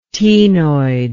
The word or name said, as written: ctenoid